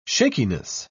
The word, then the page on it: shakiness: British English